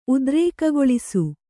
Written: ಉದ್ರೇಕಗೊಳಿಸು

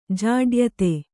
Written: ಜಾಡ್ಯತೆ

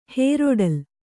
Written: ಹೇರೊಡಲ

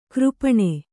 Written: ಕರಪಾಣಿ

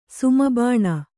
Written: ಸುಮಬಾಣ

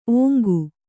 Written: ಓಂಗು

